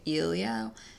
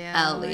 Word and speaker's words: Elio: And I mean, I know we're in the book, we're hearing it specifically from, I guess, sorry, we've been pronouncing it Elio. It's actually Elio.